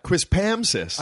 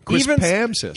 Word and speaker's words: Quispamsis: You said it right. You just put the accent on the Pam. I think that's cool. Quispamsis.